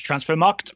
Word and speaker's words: Transfermarkt: Okay, first of all, it's pronounced Transfermarkt. – Mm-hmm. – there's no second D, right? – No, there's not.